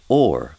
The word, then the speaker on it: hour: Well, there's nothing very funny in that until you realise that in OP, hour would have been pronounced or, which is the same pronunciation as whore.